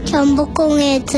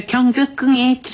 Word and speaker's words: 경복궁: 경복궁에 들어갈 수 있어요. – 광장이 되게 유명해요. – 아유, 발음도 제대로 안 되면서 경극궁에 들어갈 수 있어요.